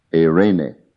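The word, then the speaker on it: Irene (εἰρήνη): In Greek, it's E-I-R-E-N-E, pronounced Irene, very much like the Spanish name of the woman, Irene, Irene, it means peace.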